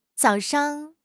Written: zao3 shang5

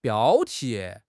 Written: 标题

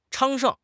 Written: 昌盛

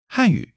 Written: Han4 yu3

